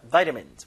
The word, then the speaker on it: vitamins: 발음할 때 비타민이 아니라 vitamins. 물론 영국 발음은 vitamin입니다.